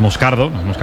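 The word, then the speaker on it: Moscardó: Como bien ha pronunciado Unai, es Moscardo, es brasileño, sí.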